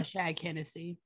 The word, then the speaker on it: O'Shaughnessy: Dana, you pronounced that wrong. It's okay. O'Shaughnessy. – I was like, I'm pretty sure I didn't, but okay, I'll listen to you. It's, oh yes, you're right. Brooke O'Shaughnessy, who began employing cannabis.